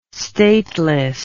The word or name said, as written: stateless